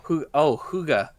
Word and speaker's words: hygge: It's spelled Hygge. It's huga.